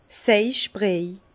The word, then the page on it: Seich-Briej: Bas Rhin; Herrlisheim